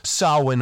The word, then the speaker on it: Samhain: Now, if you look up the spelling, it's S-A-M-H-A-I-N, but it's pronounced Samhain.